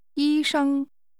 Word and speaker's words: 医生: 医生。生, 医生, doctor is pronounced with two high-level tones.